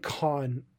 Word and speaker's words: Kaun: But while everyone is like still so locked in on Brian Cage, I think Khan is like the really like standout dude in this trio. And it's not Tony Khan, it's spelled K-A-U-N.